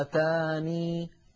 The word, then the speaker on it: آتاني: إذا قالت اللجنة في لفظ آتاني في سورة النمل وجهان لحفص وقفا، ولاحظوا كلمة وقفا، أحدهما إثبات الياء ساكنة، إثبات الياء حالة كونها ساكنة، الساكنة إعرابها حال، وثانيهما حذفها مع الوقف على النون كما نطقتها أمامكم الآن، فما آتاني أو فما آتان، يكفي القارئ أن يأتي بأحد هذين الوجهين. أما في حال الوصل فتثبت الياء مفتوحة كما ذكرنا.